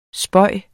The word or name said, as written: spøge